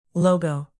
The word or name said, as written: logo